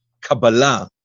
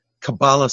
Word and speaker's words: Kabbalah: And another thing about not just the title, but even the pronunciation, we rather use the Kabbalah, the Hebrew version of the pronunciation, which is, you know, Kabbalah sounds very American.